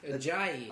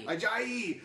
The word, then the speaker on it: Ajayi: I like it, especially with all the hamstring and, and uh, issues that uh, how do you say it? Is it a G? Ajayi. Ajayi.